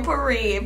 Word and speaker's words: potpourri: P-O-T. – P. And this is why English is so fucking stupid. Where the fuck do you say T? Where's the T coming? – Potpourri. – Potpourri. – The P. – Pottery. – The T is silent.